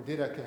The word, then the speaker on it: Didache: Yeah, or Didache. How do you say it in Greek? Do you know? D-I-D-A-C-H-E. Didache. Didache.